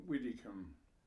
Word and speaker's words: Widdicombe: John Widdicombe. – And how would you spell it? – W-I-D-D-I-C-O-M-B-E.